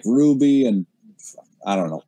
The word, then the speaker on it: RWBY: So RWBY, pronounced Ruby, is an American anime style animated web series.